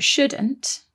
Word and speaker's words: should: One more thing about shouldn't and should is that they contain another silent letter, don't they? – There is a silent L in there, S-H-O-U-L-D, should.